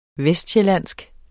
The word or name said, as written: vestsjællandsk